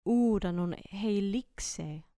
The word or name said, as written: uranon heilixei